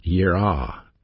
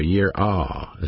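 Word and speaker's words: yirah (יראה): The Hebrew word for fear is pronounced yirah, Y-E-A-R-A-W, yirah, that's the way you pronounce it.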